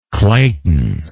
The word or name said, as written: clayton